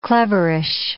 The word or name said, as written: cleverish